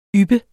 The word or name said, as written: yppe